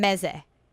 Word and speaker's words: meze: I want to get some meze, okay? – Oh, meze, you're not going to go wrong there. – Meze are Sephardic appetizers. Am I saying it right? – Yes. Absolutely.